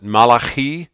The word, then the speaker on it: Malachi: Malachi, or as a Jewish person would pronounce it, Malachi.